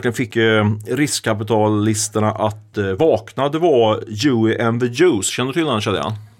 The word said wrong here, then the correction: Joe and the Juice: Joe and the Juice, absolut.